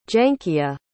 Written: Jankia

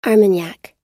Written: armagnac

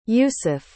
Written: Yousif

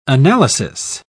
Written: analysis

